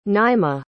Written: naimah

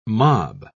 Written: mob

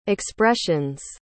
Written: expressions

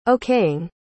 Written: okaying

